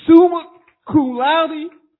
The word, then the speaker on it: summa cum laude: I know they mentioned it, but they tell you summa cum laude.